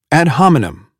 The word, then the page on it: ad hominem: American English